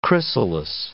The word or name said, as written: chrysalis